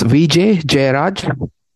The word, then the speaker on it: Vijay Jayaraj: It's Vijay Jayaraj.